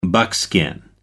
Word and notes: buckskin: American English

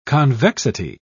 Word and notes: convexity: British English

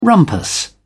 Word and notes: rumpus: British English

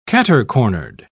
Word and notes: cater-cornered: British English

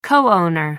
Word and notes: co owner: American English

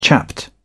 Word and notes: chapped: British English

